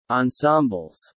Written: ensembles

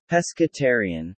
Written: Pescetarian

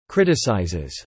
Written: criticizes

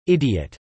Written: idiot

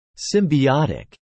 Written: symbiotic

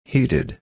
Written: heeded

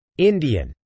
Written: Indian

